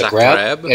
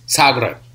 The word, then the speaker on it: Zagreb: So when I got to Croatia in Zagreb, which I learned to pronounce on the last day I was there, I thought it was Zagreb. It's Zagreb.